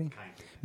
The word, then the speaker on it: Kindt: I don't know if it's Kint or Kint. Do you know anybody? – Kind. – Matt Kint, K-I-N-D-T. – Mm-hmm. – It's Mind, M-G-M-T is the way it's spelled.